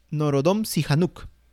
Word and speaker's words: Norodom Sihanouk: Así es, logra su independencia de la mano del el rey en aquel momento del país, que era Norodom Sihanouk. Bueno, espero que esté bien pronunciado. ¿Cómo, cómo, cómo, cómo? ¿Cómo era, cómo era? Norodom Sihanouk.